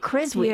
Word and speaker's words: Creswick: That's that W's there, but you don't pronounce the W. And I can't bring myself to say Creswick. – But there are people who live in Creswick. Everyone who lives in Creswick in Victoria, Australia, pronounces it Creswick.